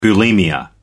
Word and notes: bulimia nervosa: British English

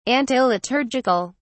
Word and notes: antiliturgical: British English

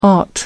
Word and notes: ought to: American English

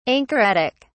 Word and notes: anchoretic: British English

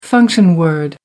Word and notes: function word: American English

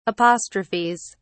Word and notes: apostrophes: British English

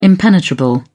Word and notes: impenetrable: British English

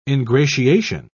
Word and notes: ingratiation: British English

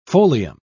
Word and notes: folium: British English

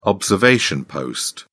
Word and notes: observation post: British English

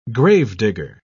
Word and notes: gravedigger: British English

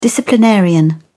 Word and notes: disciplinarian: British English

